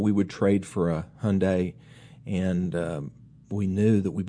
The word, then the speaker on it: Hyundai: You know, early on, there were a lot of commercials say, how do you say Hyundai? Well, it rhymes with Sunday.